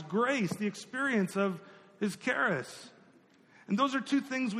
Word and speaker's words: charis: Sounds like Paris, but it's pronounced charis.